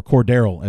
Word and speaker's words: Cordarrelle: or Cordarrelle as his name as needs to be pronounced.